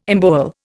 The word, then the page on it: embowel: Indian